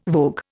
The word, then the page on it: vogue: Indian